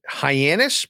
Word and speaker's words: Hyannis: Is it Hyannis, Massachusetts? – Correct.